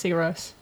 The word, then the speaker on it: Sigur Rós: So I think it's Sigur Rós. – Yeah. Wow. – That little dash on the O makes a long O sound.